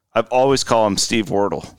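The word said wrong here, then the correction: Wirtel: Wortel. I'm pretty sure it's Wortel.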